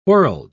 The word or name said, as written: whorled